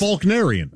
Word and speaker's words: Faulknerian: There's no L. – I don't pronounce. You don't pronounce. You say Faulkner. – Right. – Yeah, it's Faulknerian.